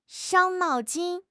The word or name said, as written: 伤脑筋